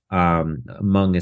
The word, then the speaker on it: Hmong: And it's about a Hmong child, Hmong is spelled H-M-O-N-G,